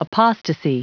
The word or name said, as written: apostasy